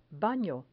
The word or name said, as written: bano